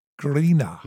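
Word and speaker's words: Grenå: Oh, I'm from a little town in Denmark, called Grena. Grena. – Okay. – Not in Danish though, but it's how we pronounce it internationally.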